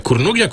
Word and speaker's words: Kurnugia: Jak to się w ogóle, że tak powiem, prawilnie, poprawnie wymawia? Kurnugia. – Tak. – Czyli z Polska, tak? – Tak, po polsku.